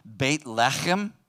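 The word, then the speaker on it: Beit Lechem (בית לחם): Beit Lechem, as it's pronounced in Hebrew, Beit Lechem literally means house of bread.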